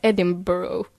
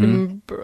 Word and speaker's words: Edinburgh: Vissa säger man ska säga Edinburgh. – Mm. Edinburgh.